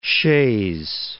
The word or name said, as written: Shays